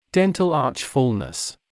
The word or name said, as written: dental arch fullness